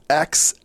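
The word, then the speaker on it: X Æ A-12: It's X-A-12. – Okay. – So the A, that symbol that's like an A and an E combined. – Right. – Is Ash. So it's X-A-12.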